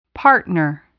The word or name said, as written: partner